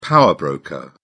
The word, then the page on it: power broker: British English